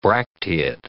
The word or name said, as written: bracteate